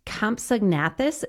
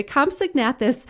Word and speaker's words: Compsognathus: I'm going to say this wrong. Compsognathus, is that correct? – I think that's pretty close. – Okay. The Compsognathus.